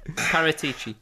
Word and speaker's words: Paratici: Is that how you pronounce that? Paratici? – Paratici. – Paratici. I think I pretty much nailed it, to be honest with you.